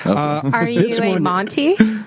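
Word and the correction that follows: Mountie: Is it Mounty or Monty? – I don't know what you're talking about. – The Canadian. – Did you pronounce it wrong, too? Mounty. Oh, my God. – It's a Mounty. Mounty? Yes.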